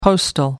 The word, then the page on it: postal: American English